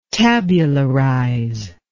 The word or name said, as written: tabularize